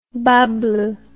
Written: bas bleu

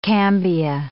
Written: cambia

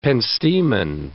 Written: penstemon

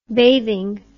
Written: bathing

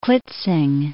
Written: Klitzing

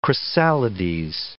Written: chrysalides

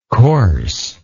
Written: coarseness

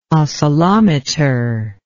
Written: oscillometer

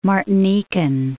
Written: Martinican

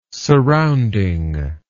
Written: surrounding

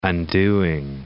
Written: undoing

